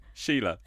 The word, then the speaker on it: Shelagh: Sheila. S-H-E-L-A-G-H. Sheila.